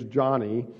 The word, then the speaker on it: Joni: So even though it's J-O-N-I, it's not Joni, it's Johnny.